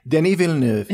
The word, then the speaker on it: Denis Villeneuve: Denis Villeneuve. He's French-Canadian. Oui. And I heard that pronounced that way by a person in the know once, and I'm going with it.